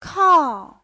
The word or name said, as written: kao4